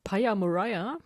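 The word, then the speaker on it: Pia Maria: Ich würde mal anfangen mit Paya Mariah. Das habe ich so aus der Aussprachedatenbank. Also das, da bin ich mir sehr sicher, dass man sie so ausspricht.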